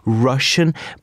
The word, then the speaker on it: Rvssian: His name is Russian, but you spell it R-V-S-S-I-A-N. Mm. And the reason why? Because it was very hard to search for him on Google. So he said, let me add a V. So it becomes easy, but you still pronounce it as Russian.